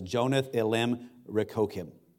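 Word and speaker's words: Jonath-elem-rechokim: It says, to the chief musician upon, and I looked this up because I wanted to know how to pronounce this, and I listened to it over and over again, and it says, Jonath Elim Rekokim.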